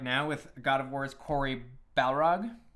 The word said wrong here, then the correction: Barlog: I just got his name wrong. It's Barlog.